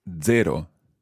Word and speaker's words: zero: It's zero. – Zero. – Zero. – Zero. – It's like a DZ sound at the beginning.